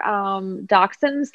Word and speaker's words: dachshund: He was like, yeah, you know, like that story where there were dachshunds that are dachshunds? Is that how you say it? – I think it's Dachshund.